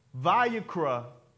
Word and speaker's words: Vayikra: Vayikra is how you say it.